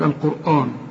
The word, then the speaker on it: القرآن: القرآن برضو ناس كتير قالتقولك القرآن، يقولك القرآن، آن ايه برضو؟ آن، زي ما تقول الآن، تقول لي ايه الآن؟ الآن يا أخي، هنا علم القرآن.